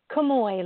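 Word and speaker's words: Kamoie: Kamoi, like it's O-Y.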